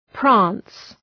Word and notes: prance: præns

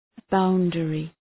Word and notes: boundary: ‘baʋndərı

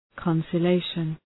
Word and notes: conciliation: {kən,sılı’eıʃən}

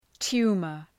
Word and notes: tumour: ‘tu:mər

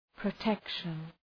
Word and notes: protection: prə’tekʃən

